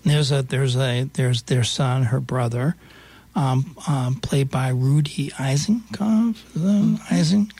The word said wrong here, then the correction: Eisenzopf: How do you pronounce Eisenzopf.